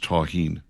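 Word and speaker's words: tahini: I'm being told, by the way, by our texters that it's pronounced Taheen. – Taheen. Oh, thank you for the correction.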